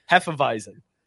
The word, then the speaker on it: Hefeweizen: Hefeweizen. – Yeah. – Hefeweizen. Fuck yeah. – Yeah. But Tank also pointing out that we now need to get a Mexican wheat lager called Hefeweizen.